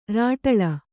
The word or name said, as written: ಱಾಟಳ